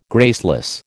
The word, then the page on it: graceless: American English